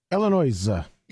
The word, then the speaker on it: Illinois: It's French, and the S is silent, but I like saying what? – Illinois.